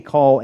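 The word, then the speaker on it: Qal: But notice here, in the Hebrew, the word for angry, when it says that David was angry, it's in what we call a cal form, a Q-A-L is how you would spell it.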